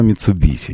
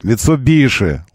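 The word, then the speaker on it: Mitsubishi: У нас Митсубиши называется бренд в России. Нет, они все равно Митсубиси. Нет, все равно у них Ламборджини. Ламборджини, вот это все. Нет, Митсубиши, Ламборгини, Хончи. Вот как все это произносится.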